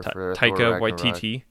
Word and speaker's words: Waititi: The only problem is Yon Ivett's director, I don't know how to pronounce, what is it, like Taika Waititi or whatever? – Taika Waititi. – Waititi, yes. Yeah.